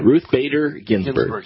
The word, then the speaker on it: Ruth Bader Ginsburg: Ruth Bader Ginsburg.